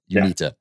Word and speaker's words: UNITA: U- is that how you say it? Uh, UNITA. UNITA, yeah. uh, movement yeah.